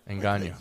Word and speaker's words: Ngannou: Ngannou.